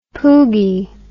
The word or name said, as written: poogye